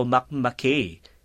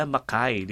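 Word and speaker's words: Mackay: Pero nung una kong nabasa yan, uh, Martin, at mga kababayan, nakala ko Makmakay.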